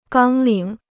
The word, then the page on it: 纲领: gāng lǐng